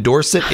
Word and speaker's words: Dorset: In 2025, UK Dog Surfing Championship in Dorset, England. Am I saying that right? Dorset? – Yeah, that's correct.